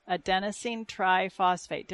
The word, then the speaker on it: adenosine triphosphate: Phosphorus is what the muscles use to contract adenosine triphosphate. Did I say that right? – That's very good.